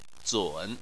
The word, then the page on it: zun: 3